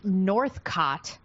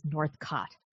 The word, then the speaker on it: Northcott: It's Northcott, but it's spelled like I'm seeing North Scott in a lot of places, but it is Northcott.